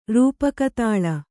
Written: ರೂಪಕತಾಲ

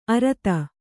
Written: ಅರುದು

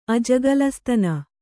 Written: ಅಜಗಲಸ್ತನ

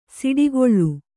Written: ಸಿಡಿಗೊಳ್ಳು

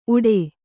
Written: ಊಡೆ

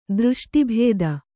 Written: ದೃಷ್ಟಿಭೇದ

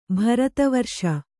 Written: ಭಾರತವರ್ಷ

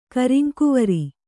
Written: ಕರಿಂಕುವರಿ

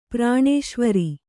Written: ಪ್ರಾಣೇಶ್ವರಿ

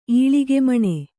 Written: ಈಳಿಗೆಮಣೆ

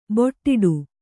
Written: ಬೊಟ್ಟಿಡು

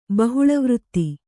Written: ಬಹುಳವೃತ್ತಿ

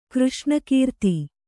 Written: ಕೃಷ್ಣಕೀರ್ತಿ

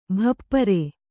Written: ಭಪ್ಪರೆ

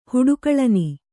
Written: ಹುಡುಕಳನಿ

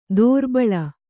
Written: ದೋರ್ಬಲಿ

